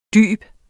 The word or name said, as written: dyb